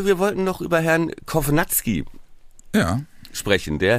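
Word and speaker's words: Kownacki: Nein, Kovnatski ist tatsächlich die richtige Aussprache.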